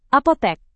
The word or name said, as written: apotek